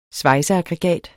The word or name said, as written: svejseaggregat